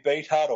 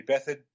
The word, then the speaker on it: Beathard: I mean, Bobby Beathard or Bobby Bethard, if you want to pronounce it correctly,